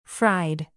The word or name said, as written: fried